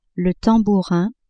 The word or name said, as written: tambourin